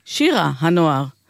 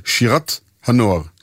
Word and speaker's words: שירה הנוער: אנחנו מדגישים את ההגייה הנכונה, כי נשתגרה בפי רבים התפיסה שהשיר מדבר על שירת הנוער, כאילו זאת צורת סמיכות. ובכן, שירת הנוער היא באמת שיר עתידנו, אבל דוד מערבי כתב כאן בציווי, שירה הנוער.